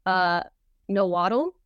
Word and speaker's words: Nahuatl: And the specific Aztec language, I'm going to try and pronounce this as best as I can. Nahuatl. It is spelled N-A-H-U-A-T-L.